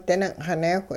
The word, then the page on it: dining'xineewh: hupa